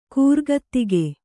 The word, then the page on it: ಕೂರ್ಗತ್ತಿಗೆ: kannada